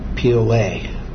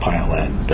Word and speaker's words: Piollet: Sarah Piolet, anglicized now, they call it Piolet. – How did she spell that? – P-I-O-L-L-E-T. It's a French name.